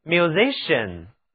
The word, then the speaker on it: musician: 伟大的作曲家可以谱写出传世的乐曲，就像贝多芬、莫扎特，他们都是 musician， musician，音乐家、作曲家，就是在 music 后面添上表示人的名词后缀 ian，字母 c 在音乐家这个词中读作 sh，请跟我读 musician， musician。